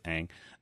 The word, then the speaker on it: Aang: Pronouncing it right. It's Aang. – Aang is the right way.